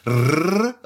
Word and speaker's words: RRR: nämlich am 7.1.2022 und dabei geht es um Rrrr oder ausgesprochen Rise, Roar, Revolt.